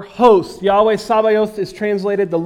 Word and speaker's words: Sabaoth: And so it is pronounced Sabaoth or something similar to that. I spell it S-A-B-A-O-T-H. Sabaoth, Zavayot. It's somewhere in that pronunciation.